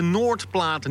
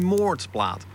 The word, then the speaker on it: Noordplaat: Er heeft vroeger een hele grote eenekooi gelegen, tot aan Napoleon, die de Noordplaat niet kon uitspreken en die op het Frans zei moordplaat.